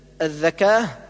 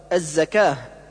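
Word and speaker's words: الزكاة: والعكس كالنطق بالزاي ذالا، مثل الذكاه، والصواب الزكاه.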